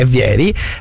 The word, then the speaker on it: Vieri: È vero che c'è Vieri senza accento sulla I, perché non è francese, no?